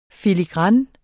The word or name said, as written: filigran